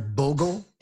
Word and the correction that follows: Borgel: Oh, Borgel. Yes.